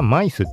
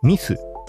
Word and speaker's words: Myth: マイスって俺言ったけどあの読み方全然違いました。シーシュポス・ザ・ミスでした。